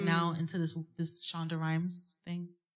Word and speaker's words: Shonda Rhimes: It's Shonda Rhimes. I said it right? Shonda Rhimes.